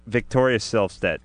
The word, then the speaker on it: Victoria Silvstedt: Victoria Silvstedt. Wait a minute. Did I say that right? – You got it.